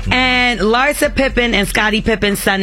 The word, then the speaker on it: Larsa: Larsa. That's how you say her name.